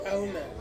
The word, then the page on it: au: Ansus